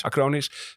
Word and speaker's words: Acronis: Acronis staat erop in plaats van Ziggo.